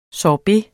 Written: sorbet

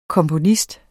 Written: komponist

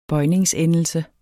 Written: bøjningsendelse

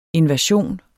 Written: invasion

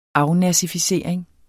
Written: afnazificering

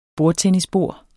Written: bordtennisbord

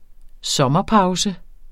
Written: sommerpause

